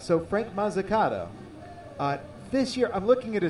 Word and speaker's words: Mazzucato: Tomorrow's starting pitching matchup, Grant Judkins against Frank Mazzucato. Did I pronounce Frank's last name right? – Mazzucato is correct.